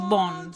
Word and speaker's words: Vaughan: Pero se pronuncia Vaughan, Sarah Vaughan.